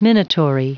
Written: minatory

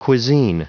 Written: cuisine

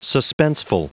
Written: suspenseful

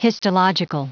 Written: histological

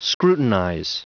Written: scrutinize

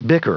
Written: bicker

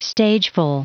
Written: stageful

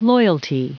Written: loyalty

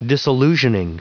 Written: disillusioning

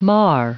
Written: mar

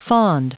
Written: fond

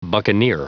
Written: buccaneer

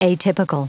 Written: atypical

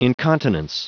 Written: incontinence

